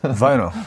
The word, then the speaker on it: Vinyl: 어떤 표현, 얘기 원래 발음은? 바이너. 아, 원래 발음. 아, 원, 원 발음. – 버터. 예. 네네네. BTS 근데, Butter. 예. Vinyl. Vinyl인데 예. 이제 일본식 표기로. 그렇죠. 네, 비닐. 비닐, 비닐. 원래 원래는 이렇게 안 하죠. 세 음절로 하죠. 비닐로.